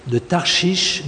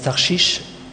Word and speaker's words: Tarsis: On pense que la ville de Tarsis, certains prononcent Tarshish,